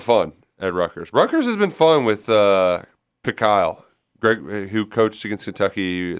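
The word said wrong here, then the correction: Pikiell: Say his name again. – I don't know why I said Pekile. Tough as nails, Pekile. – Pikel. – I don't know.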